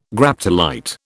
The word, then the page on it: Graptolite: British English